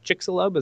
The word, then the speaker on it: Chicxulub: Was it Chicxulub? Is that how you say it? The one that killed the impact? – I think you got it right. Very well done.